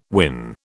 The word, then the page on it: win: British English